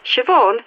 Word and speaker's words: Siobhan: My name is Siobhan Desmond. – How do you spell that? I really only need your first name. – Siobhan. S-I-O-B-H-A-N. It's an Irish name. – Thanks. So this was Siobhan. S-I-O-B-H-A-N.